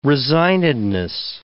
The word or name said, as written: resignedness